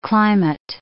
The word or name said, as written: climate